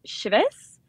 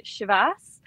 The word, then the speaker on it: Chivas: Certainly, back home in Speyside, where I'm from, where I grew up, my friends, family, and colleagues back there are all pronouncing it Chivas. And that's certainly how I grew up pronouncing it. But I will say, since I've moved to Singapore, I actually have changed my pronunciation a little bit. I think just to help me be a bit more understood. So I've started leaning towards the Chivas. But I would say back home, it's Chivas.